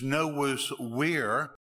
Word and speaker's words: Novus Vir: Now, problems with that is if you pronounce it in the ancient Latin pronunciation of V as a W, it's Novus Vir, and that sounds too much like weird.